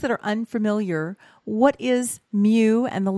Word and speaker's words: Mu: And we pronounce it Mu, like Mu the cow.